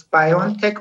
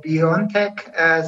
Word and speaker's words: BioNTech: Yeah, you can pronounce it as BioNTech or BioNTech. So it is. We did not dictate how the company should be pronounced. – So I'm going to go with BioNTech, actually.